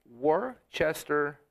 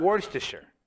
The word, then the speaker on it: Worcestershire: To be cool, I always want to say it properly. It's Worcestershire sauce, not Worcestershire, even though that works too.